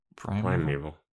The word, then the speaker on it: Primeval: Is that, am I pronouncing that right? It's Prime V-A-L. – No. – Primeval. – Primordial? Primordial? Primordial? This is where people realize we can't read. – Yeah. This is where, you know, I've been discovered to be illiterate this whole time. Hold on, let me Google. – Who wrote those show notes that you posted on Discord? – Oh, God. Hold on. Yeah, Primeval. I just went to Google and had it pronounce it for me. Primeval.